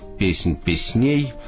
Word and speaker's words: Песнь песней: называется он «Песнь песней», именно с ударением на втором слоге предлагается произносить это название, и с этой самой «Песни песней» послушаем народную песню, вроде бы как...